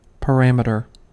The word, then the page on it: parameter: American English